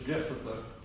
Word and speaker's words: Jephthah: And we might, as I said, might pronounce that in English, Jephthah, but it really has more of a guttural sound in the Hebrew.